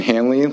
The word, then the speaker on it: Hanley: My name is Ethan Hanley, last name is spelled H-A-N-L-E-Y.